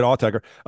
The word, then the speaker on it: Autechre: Is that how you say it? Autechre? – That's how I say it. – Okay, well, I'll say it Autechre.